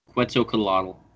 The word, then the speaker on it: Quetzalcoatl: Quetzalcoatl.